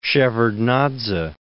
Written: Shevardnadze

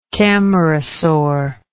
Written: camarasaur

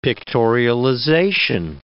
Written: pictorialization

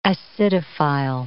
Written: acidophile

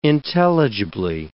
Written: intelligibly

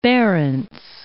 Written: Barents Sea

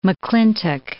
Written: McClintock

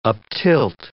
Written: uptilt

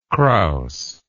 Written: kraus

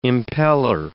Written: impellor

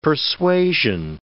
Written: persuasion

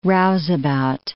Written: rouseabout

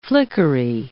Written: flickery

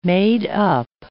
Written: made-up

